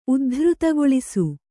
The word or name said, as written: ಉದ್ಧೃತಗೊಳಿಸು